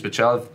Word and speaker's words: Pichel: Like, Vince Pichel, that's how you pronounce the name.